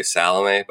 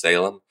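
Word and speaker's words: Salame: I used to call it, say Salome, but I think it's Salem.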